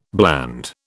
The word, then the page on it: bland: British English